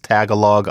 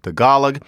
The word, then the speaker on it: Tagalog: Languages of the Philippines, the one you're most likely to have heard of, you might think it's pronounced Tagalog. I wish it was, but it's Tagalog.